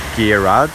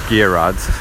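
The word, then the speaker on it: Gearóid: So if you do a translation of Gareth from Welsh to Irish, you get Gearad, which it would phonetically sound like Jared or Gerald.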